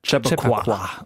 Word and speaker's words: Chappaqua: Wie spricht man es aus? – Chappaqua.